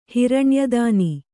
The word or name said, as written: ಹಿರಣ್ಯದಾನಿ